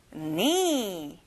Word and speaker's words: knee: 발가락, toe, toe, excellent, 무릎, knee, don't forget, 앞에 K 소리 안 나요, knee, good job, 몸, body, body, excellent, 너무 잘했어요.